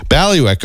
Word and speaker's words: bailiwick: B-A-I-L-I-W-I-C-K. – Okay. – So like Bally and then Wick. – Ballywick.